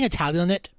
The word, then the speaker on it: Italianate: I keep saying Italianate because it's spelled Italian, A-T-E.